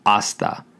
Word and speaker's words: hasta: Again, that word is hasta. It's spelled H A S T A. Remember, the H in Spanish is silent at the beginning of a word.